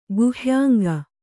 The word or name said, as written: ಗುಹ್ಯಾಂಗ